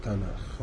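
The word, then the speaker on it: تناخ (תנ״ך): ماشي، يعني العهد القديم كتب اليهود في مسمى اليهود اسمه تناخ.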